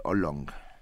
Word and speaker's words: oolong: ¿Cómo se conoce comercialmente el oolong? – El oolong, así como... – Oolong. – Ajá, oolong. Eh, se escribe con doble O, oolong.